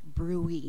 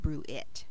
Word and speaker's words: bruit: It's a French word, so it's pronounced bruit, not bruit.